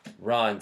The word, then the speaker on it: Ran: Ron is, I think, more appropriate.